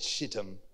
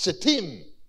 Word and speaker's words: Shittim: Louis said Shittim. It's Shittim. That's how you pronounce it.